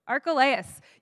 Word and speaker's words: Archelaus: Does anyone know how to say it? Okay. Archelaus.